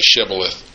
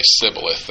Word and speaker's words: shibboleth: And one translation I saw said that people could say shibboleth, or if they were foe, they would say sibboleth. They couldn't make the SH come out right.